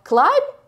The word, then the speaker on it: climb: А вот когда уже что-то в английском, например, слово climb, которое пишется как BM, но это B не читается,